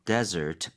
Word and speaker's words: desert: 네, 여기 desert, 강세 de 쪽에 주는 거 desert가 아니라고 했습니다. – 네, right? 스펠링도 주의하셔야 돼요. Mm-hmm. D-E-S-E-R-T.